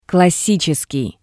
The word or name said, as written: classitseskii